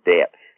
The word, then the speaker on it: Debt: And, you know, one of the things for me, the best thing about this whole book is the character of Debt. Like, and I'm sure that that's like D-E-B-T as well. – That's how you say it, yeah.